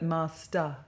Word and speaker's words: master: Not er, but master. – Master. – No, ah. Master. – Master. – Master. – Master. – It's an ah sound at the end of master.